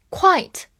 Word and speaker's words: quite: quite 表示相当，它末尾是 te，所以末尾的 e 不发音；而 quiet 把 e 放到了 t 前面，它是要发 a 这个音的，所以读作 quiet，quiet。